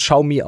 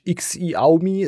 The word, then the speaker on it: Xiaomi: Es wird Xiaomi ausgesprochen, nicht X-I-Aumi, es wird Xiao. Wie Schaum und I, Xiaomi, hat das ausgesprochen.